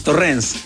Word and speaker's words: Torrens: Torrens, claro. Ah, era con acento en la E ahí, Torrens.